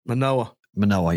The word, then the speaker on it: Manoah: How do you say Alex? – Manoah, yeah.